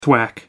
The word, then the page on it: thwack: American English